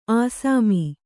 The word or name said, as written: ಅಸ್ಸಾಮಿ